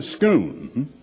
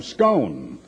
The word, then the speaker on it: Scone: People recently had a little of their faith shaken because they have moved the stone of Scone, as they pronounce it over there.